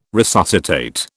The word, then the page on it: resuscitate: British English